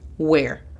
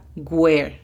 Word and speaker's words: where: La primera, where. A menudo se pronuncia where. Where. Hacen la pronunciación de la W como si fuera una G, cuando la pronunciación correcta pareciera que es con la U. Imagina que hay una U y después está la E. Where. No, where. Pasamos a la segunda palabra.